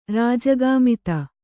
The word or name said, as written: ರಾಜಗಾಮಿತ